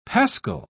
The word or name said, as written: pascal